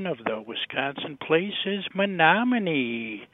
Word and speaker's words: Menominee: The pronunciation of the word in question is Menominee.